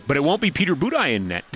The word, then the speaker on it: Budaj: It's pronounced Budaj.